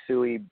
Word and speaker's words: Tsui: You can get a hold of me, through Facebook, my name, Bobby Sui. My last name is spelled T-S-U-I.